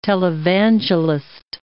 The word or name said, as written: televangelist